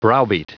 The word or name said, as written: browbeat